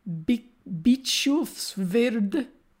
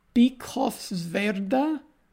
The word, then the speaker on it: Bischofswerda: Its author, Abedrushan, christened Oscar Ernest Bernhardt, was born on 18th of April, 1875 in Bischofswerda, Germany. I might be pronouncing that incorrectly, but I'm sure it's a place in Germany. Bischofswerda. – Bischofswerda. – Bischofswerda. – Yeah. – Is that how I say that?